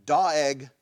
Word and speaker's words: Doeg: The second thing is, there's a guy in this story named Doeg the Edomite. That's how you pronounce his name. It's D-O-E-G, Doeg.